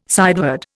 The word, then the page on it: sideward: Indian